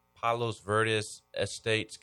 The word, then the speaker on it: Palos Verdes Estates: Palos Verdes Estates, California.